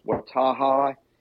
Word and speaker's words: Outtara: His last name is pronounced Wattaha. It is spelled O-U-T-T-A-R-A.